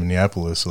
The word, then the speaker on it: Minneapolis: So I wanted to know, and I'll see if I can even pronounce this again, how it was growing up in Minneapolis. What do people do there? How is that place? – Good job. You said it right. So Minneapolis, I think, it's really underrated.